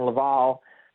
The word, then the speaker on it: Laval: Laval, I should say, and pronounce that correctly. – Well done.